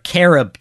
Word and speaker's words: carob: Carob chips.